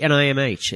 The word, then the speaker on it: NIMH: It's actually NIMH. It's an acronym. – The ninja for the dimension. – Hold on, say that again. NIMH. NIMH. Oh, Nim.